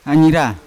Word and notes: anyira: Yawa

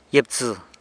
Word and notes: gyibtsë: sanlucasquiavini

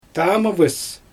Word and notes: taamɯvɯs: Xyzyl

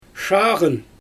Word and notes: ʃaaɣɯn: Xyzyl

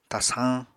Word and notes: tasañ: Apatani

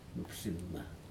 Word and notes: nupsinma: Aneityum

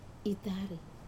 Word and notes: tare: Wamesa

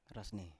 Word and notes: rasne: Biak

